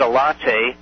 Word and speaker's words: ELATE: And the Russians created their own company called ELATE, E-L-A-T-E, pronounced a latte.